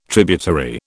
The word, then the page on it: tributary: British English